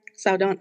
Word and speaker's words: Saldana: I don't like Zoe Saldana, though. Is that how you fucking say her name? Saldana? Mm-hmm.